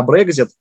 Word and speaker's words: Брекзит: это в том числе ответ на Брекзит и на, в общем-то, недружественную политику Великобритании в отношении, прежде всего, Франции и Германии в том числе. – Да, вот вы предвосхитили правильное ударение, да, в этом сложном слове.